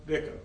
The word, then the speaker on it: Vico: You know, I was reading another guy you mentioned, Vico, how do you pronounce it? – Vico, okay.